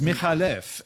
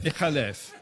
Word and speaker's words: McAuliffe: McAuliffe is actually an Arabic name And it's Michalev, and the C is just for spelling pronunciation. – Right, Michalev. – Michalev.